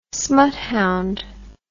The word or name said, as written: smuthound